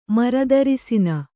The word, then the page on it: ಮರದರಸಿನ: kannada